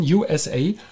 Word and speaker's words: Usa: The writing is USA, yeah? But the name is USA in German pronunciation, USA.